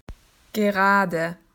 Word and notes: straight: Australian English

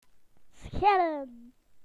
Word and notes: to peel: British English

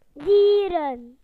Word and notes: animals: British English

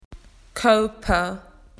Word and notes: body: Australian English

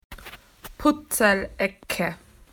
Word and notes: puzzle corner: Australian English

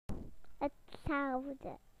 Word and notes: the same: British English

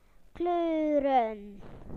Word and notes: colours: British English

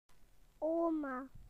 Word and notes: grandmother: British English

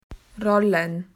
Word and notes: to roll: Australian English

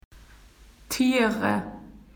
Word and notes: animals: Australian English

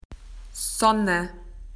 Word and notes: sun: Australian English

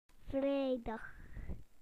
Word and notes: Friday: British English

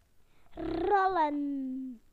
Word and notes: to roll: British English

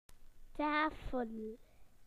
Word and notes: table: British English

